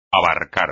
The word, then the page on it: abarcar: espagnol